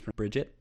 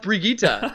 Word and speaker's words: Brigitte: I believe it's Bridget. – It is pronounced Brigitte.